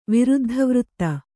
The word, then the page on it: ವಿರುದ್ಧವೃತ್ತ: Kannada